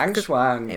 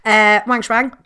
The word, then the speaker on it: Wang Shuang: Just Wang Shuang. – It rhymes. Wang Shuang, great hair, looks great in a suit.